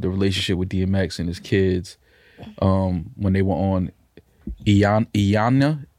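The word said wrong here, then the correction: Iyanla: how do you say her name? Iyana Van Zandt. – Ayanla.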